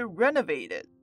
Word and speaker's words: renovated: 有些芬兰的灯塔被重新翻修后成了饭店，所以你可以到芬兰的海岸来场冒险，在这很不一样的地方睡上一晚。好，那现在来看到发音重点吧。今天一样有两个发音重点，第一个是 renovate 这个字，renovated，要注意后面的 o 它发的是 a，所以是 renovated，不是 renovated。Renovated. 接下来第二个发音重点要看到 sleep 跟 in 的连音 sleep in.